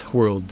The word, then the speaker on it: whirled: Do you pronounce the H in WH? And I did it in this case because it's whirled, WHIRLED.